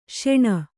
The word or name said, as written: ಶೋಣ